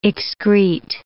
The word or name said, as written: excrete